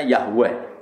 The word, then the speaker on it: Yahweh: Wong Yahudi nyembah itu tulisan kapitalnya Yahweh, Ize dengan huruf kapital, A dengan huruf kapital, H dengan huruf kapital, W dengan huruf kapital, E dengan huruf kapital, H dengan huruf, bacanya Yahweh.